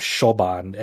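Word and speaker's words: Siobhan: mole named Shoban S I O B H A N I think that's how it's, it's pronounced is Shoban and uh eventually this mole person uh,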